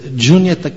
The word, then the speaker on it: Juniata: The proper pronunciation is Juniata County.